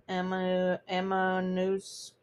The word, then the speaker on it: Ammonoosuc: And she could see a car against a snowbank along Route 112, also known as Wild Ammonoskew Road. I don't know if I'm saying that right. A-M-M-O-N-O-O-S-U-C. Ammonoosk. With a hard C, apparently.